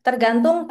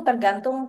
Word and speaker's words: tergantung: Tergantung, tergantung, tergantung. – Tergantung to gat tergantung to gatun to gatun gantun to wait gan to Tergantung, tergantung, tergantung.